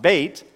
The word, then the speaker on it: Beit: The second set, 9 through 16, is under the second Hebrew letter, and it looks like Beth, but it's not pronounced Beth, it's pronounced Beit.